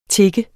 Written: TIGGEDES

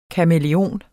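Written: KAMÆLEONEN

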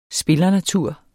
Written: spillernatur

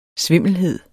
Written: SVIMLE

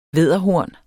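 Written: VÆDDERHORNET

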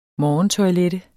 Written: morgentoilette

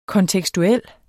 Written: kontekstuel

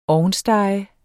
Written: ovnstege

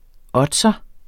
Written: oddser

